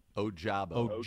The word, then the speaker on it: Ojabo: If you see Ojabo, that's the one we want to be able to get that pronunciation right. – Ojabo.